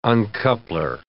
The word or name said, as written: uncoupler